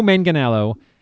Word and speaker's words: Manganiello: Joe Magliano, I wrote this down so I could get the... – How dare you mispronounce that name. – Joe Manganiello. Joe Manganiello.